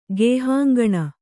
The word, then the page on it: ಗುಹಾಂಗಣ: Kannada